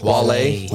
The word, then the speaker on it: Wale: Wale. W-A-L-E.